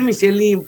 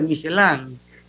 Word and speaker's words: Michelin: la gente dice Michelin, pues. De, una eh, vez. Todo el mundo, todo el mundo lo entiende, pero bueno, la forma correcta de pronunciarlo es Michelin.